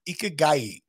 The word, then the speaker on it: ikigai: And part of that, thanks to our producer, Steve Kaufman, because you mentioned a Japanese term early on and you weren't sure of the pronunciation. Thanks again to Steve, our producer. It's ikigai, which is a reason for being.